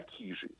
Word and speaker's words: Кижи: В Карелии ударение всегда ставят на первый слог, поэтому у них не кижи, как мы привыкли, а кижи, не любовь, а любовь.